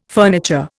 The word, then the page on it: furniture: Indian